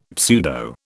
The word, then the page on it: pseudo: British English